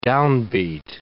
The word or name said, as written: downbeat